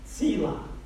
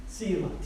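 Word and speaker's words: Sela (צלע): The rib here in the Hebrew is spelled T-S-E-L-A. Sela. Sela. T-S-E-L-A. Not to be confused with like salsa or Tesla. Some people would like to be a Tesla. It's Sela. And this means side.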